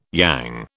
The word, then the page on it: yang: British English